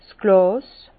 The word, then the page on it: 's Glàs: Bas-Rhin; Herrlisheim